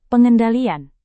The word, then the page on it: pengendalian: indonesian